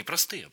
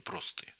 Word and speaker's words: простые: Вот в богословии есть такое понятие «простые вопросы», ударение на первый слог. Не простые, а простые.